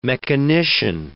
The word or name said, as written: mechanician